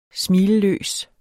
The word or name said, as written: smilløs